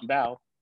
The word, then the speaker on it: Bow: It's literally Bow, B-O-W, but it depends on who says it, right?